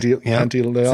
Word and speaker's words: Pantoliano: Oh, it's Joe Pantaleone. – Joe Pesci. – Nope. – Joe Pantaloon? – Pantoliano? – Okay. – Pantoliano. – Sounds right.